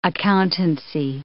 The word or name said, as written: accountancy